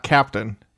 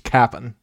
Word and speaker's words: Cap'n: No, not Captain. Just Cap'n. – Like, how do you spell it? – Like Cap'n Crunch.